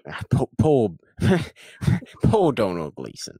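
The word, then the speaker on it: Domhnall: About time, where we'll all pronounce Donald Gleeson correctly. – Yes. – Domnall. as it's correctly pronounced. No, it isn't, obviously. – No. Only say it like that for spelling purposes.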